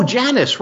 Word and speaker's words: Janus: Oh, Janus, right.